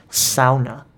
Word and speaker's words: sauna: And her family was Finnish, and they were very adamant about the way people from the U.S. pronounce the word sauna. It's actually pronounced sauna.